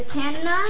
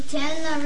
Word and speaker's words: Tanana: The first river we studied is the Tanana River. The river is a tributary of the Yukon River and is 584 miles long. The name is from the Koyuk language pronounced Tenano.